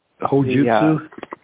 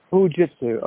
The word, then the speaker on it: Hojutsu: Hojutsu? – Hojutsu.